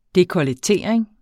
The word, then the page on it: dekolletering: dekʌləˈteˀɐ̯eŋ; ˈdekʌləˌteˀɐ̯eŋ